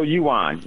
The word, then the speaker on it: Yuan: Am I saying that correctly? Is it Yuan? How do you say it? Yuan? – Yeah, the Petro, oh shoot, the Petro Yuan, yes.